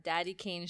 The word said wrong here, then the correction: Danity Kane: Oh, Danity Kane? – Danity Kane.